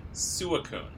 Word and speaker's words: Suicune: So, just a clarification point, I listened to a clip from the anime, and the pronunciations are Raikou, Entei, and Suicune.